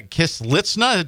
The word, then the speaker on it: Kislitsyna: He's brought along Russian pianist Anna Kislitsyna.